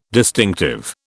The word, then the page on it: distinctive: British English